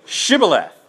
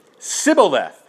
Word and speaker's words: Shibboleth: Okay, but when they would say Sibboleth, they couldn't pronounce the SH, and in Hebrew it would have been a completely different sound, so I'd have no idea what it is.